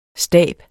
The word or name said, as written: stab